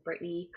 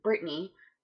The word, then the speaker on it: Britney: So it's my first name and last name, Brittany Koch, K-O-C-H, and then Brittany is spelled B-R-I-T-N-E-Y. And then on my Instagram, you'll have to put a couple underscores at the end there. So Brittany Koch underscore underscore.